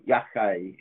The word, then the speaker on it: Yachay: Um, uh, we were looking for Marco from Cagliari, from Yachay. – Hi. – Tell me again. I always say it wrong. How do I pronounce yakai? – Yachay. – Yachay. That's what I get wrong.